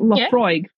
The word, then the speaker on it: Laphroaig: All I know is it's not Laphroaig. I don't know. I can't remember what some English guy called it, but he was like Laphroaig, blah, blah, blah, blah. I was just like, dude, do not pronounce every letter in that.